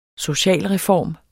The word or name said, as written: socialreform